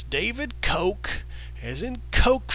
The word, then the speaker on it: Koch: It's pronounced Coke, as in Coke whore.